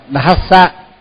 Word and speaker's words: Sambuddhassa: Thì là mình thường hay đọc là Sambuddhassa, tức là mất chữ H. Rồi thì là có khi mình lại đọc là Thassa. Thassa thì cũng thì là mất chữ D. Cho nên là phải đọc đúng là phải đọc Dhassa.